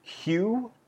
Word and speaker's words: Ryu: I said Ninja Gaiden and I said Ryu. – Ninja Gaiden? – Yeah, and it's actually Ninja Gaiden, and it's Hugh, I think, is actually the proper pronunciation.